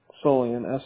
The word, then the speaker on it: Solian: Z-A-C-K. Yeah, Zachary. Z-A-C-K-E-R-Y. – Okay. And what is your last name? – Solian. S-O-L-I-A-N.